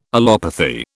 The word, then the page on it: allopathy: British English